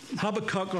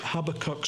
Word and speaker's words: Habakkuk: and you come to Habakkuk or Habakkuk, whatever way you want to pronounce that, is fine by me. It's used both ways. But most of you would say Habakkuk, so we'll try to use that terminology.